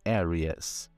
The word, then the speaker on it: Aries: In English, Áries se diz Aries.